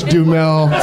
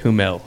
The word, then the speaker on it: Duhamel: I love that David Cross even made a Josh Duhamel reference. – Well, if you rewind it, you'll see that I mispronounced it and said Humel.